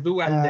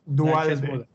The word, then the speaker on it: Duhalde: Así es, tenemos de abajo para arriba, como es costumbre, este, tenemos a, empatados en cuarto lugar, este, Iván Duhalde o Dualde, no sé cómo, no sé si lo estoy pronunciando bien, por favor díganme si lo estoy pronunciando bien. – Es Dualde. Ah, Dualde. Ok, Dualde.